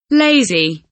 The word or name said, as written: lazy